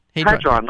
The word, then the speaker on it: Hadron: am I saying that correct? Hadron or Hadron, how do you say it? – Hadron, the Large Hadron Collider.